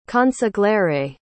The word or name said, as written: consigliere